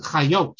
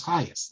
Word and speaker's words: Chayas: In Israel, they pronounce it Chayot, which doesn't make any sense because despite the fact that Suf and Tuf are the same thing in modern Hebrew, his name was Chayas. That's how he spelled it. He pronounced it S. So it doesn't make any sense to start calling him Chayot. That's not his name.